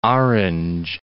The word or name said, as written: Orange Free State